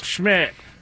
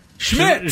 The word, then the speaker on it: Szmyt: The kicker is Andre S-Z-M-Y-T. As I said, no vowels. – Schmidt. – Schmidt. – Schmidt.